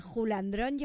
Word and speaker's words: Julandrón: no se puede ser más jefazos que el Julandrón lleva acento en la O.